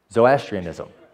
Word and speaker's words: Zoroastrianism: How do you say it? You say it better. Zoroastrianism.